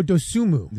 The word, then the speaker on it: Dosunmu: Ayo Dosunmu.